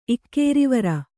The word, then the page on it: ಇಕ್ಕೇರಿವರ: Kannada